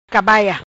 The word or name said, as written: Cabaia